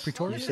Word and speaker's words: Pretorius: Dr. Edward Pretorius. Is that how you say it? I, yes, Pretorius. You said that okay.